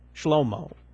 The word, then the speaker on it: Shlomo: My son is named Solomon, which in Hebrew is Shlomo. That's how it's pronounced. Shlomo.